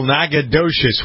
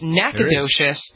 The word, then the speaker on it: Nacogdoches: But if it's Nacogdoches, then that's different. – It's Nacogdoches, but there's a G after the C.